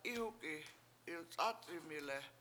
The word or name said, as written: Ihuk7i7 ilc7Ac7amile7